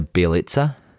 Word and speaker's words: Bjelica: I'm going to explicitly apologize because I'm going to butcher this next name, but Nemanja Jelica has gone from Miami to the Golden State Warriors. – Nemanja Bjelica? – Sure.